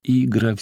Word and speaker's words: Игра: Арсенич крепко сделал ударение на первой букве. «Летит птица Игра, вся белая, с черными крыльями, вьется, кричит, хочет его, например, крыльями до смерти затрепать.